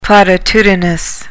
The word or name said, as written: platitudinous